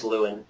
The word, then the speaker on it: Llewyn: The next movie is Inside Llewellyn, or I don't know if I'm ever pronouncing this right. – I think it's Llewyn.